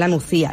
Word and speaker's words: La Nucía: Hoy nos acompañan Nuria Olcina y Noelia Lora de My Solens, que es un centro de bienestar que está en La Nucía. Lo he dicho bien, ¿verdad, chicas? – Sí.